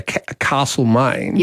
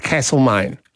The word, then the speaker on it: Castlemaine: Well, and for example, if you live in the lovely Victorian hamlet of well, as Melburnians sometimes say, Castlemaine, yes. – Locals look at you and go, it's Castlemaine. – And that would be the original pronunciation of castle.